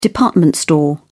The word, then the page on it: department store: British English